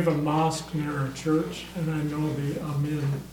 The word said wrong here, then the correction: imam: Is that how you say it? – Amin. Imam. – Amin? – Imam. – Imam. Okay.